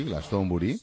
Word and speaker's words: Glastonbury: Bueno, el festival de Glastonbury, que se dice así, Glastonbury, ya tiene el cartel definitivo para este 2016.